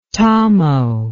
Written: tamo

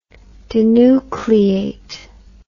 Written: denucleation

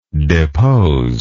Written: deposer

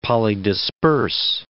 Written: polydisperse

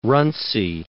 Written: Runcie

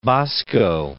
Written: Bosco

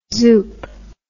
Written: zoop

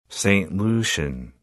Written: Saint Lucian

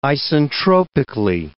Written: isentropically

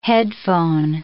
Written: headphone

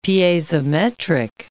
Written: piezometric

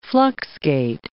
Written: fluxgate

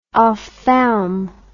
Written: ophthalm

